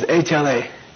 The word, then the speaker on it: lha: When you write it in Tibetan, you write the L first and the H later, but the pronunciation is the other way around.